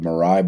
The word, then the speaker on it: Mirai: So I'm going to skip over the last story about the Mirai botnet.